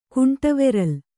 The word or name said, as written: ಕುಂಟವೆರಲ್